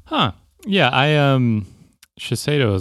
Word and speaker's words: Shiseido: And now the company Shiseido, I think that's how you say it from Japan. – Shiseido, yeah.